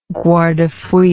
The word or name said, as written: guardafui